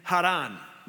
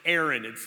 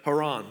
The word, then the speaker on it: Haran: And then Haran, okay, which could also be pronounced Aaron. It's kind of a spelling of, but Haran, okay.